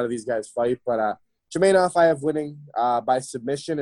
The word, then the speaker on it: Chimaev: I'm not the greatest at pronouncing names, but Chimaev, is that what it is? Chimaev? – Yep, Chimaev.